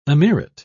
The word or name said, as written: emirate